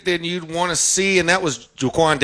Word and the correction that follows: Joaquin: Joaquin Davis, like Joaquin Phoenix, spelled the same way.